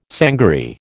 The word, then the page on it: sangaree: American English